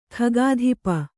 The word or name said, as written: ಖಗಾಧಿಪ